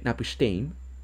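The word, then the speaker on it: Napishtim: El nombre Napistein, así se pronuncia, es una referencia al personaje Utnapishtim del épico de Gilgamesh.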